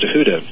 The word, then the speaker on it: Thoth: Tahuta — it's spelled T-H-O-T-H, but it's pronounced Tahuta